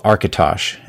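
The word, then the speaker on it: Architosh: And one of the things we wanted to mention here as we start to wrap up here is there's a website called Arkitosh, and I hope I'm pronouncing that right. – Yeah, that's right.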